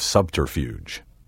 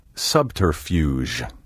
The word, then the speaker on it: subterfuge: Mystery and spy novels abound with myriad examples of the art of subterfuge. Be careful not to soften the G in this word and say subterfuge. The fuge should rhyme with huge. Subterfuge.